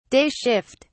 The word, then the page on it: day shift: British English